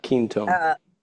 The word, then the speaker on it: Quinton: Ketone. – Ketone, Q-U-I-N-T-O-N.